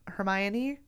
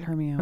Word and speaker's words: Hermione: It's also when I learned that Hermione is not pronounced Hermione. – Hermione.